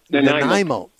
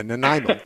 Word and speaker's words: Nanaimo: Okay, Namino, British Columbia. How do you say that? – It's Nanaimo. – Okay, yeah. It was even spelled wrong for me here, but yeah, Nanaimo.